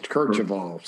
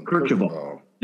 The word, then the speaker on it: Kercheval: Ben Kirkoval. I hope I'm pronouncing that right. Yes, Kercheval. Some Kercheval. – There we go.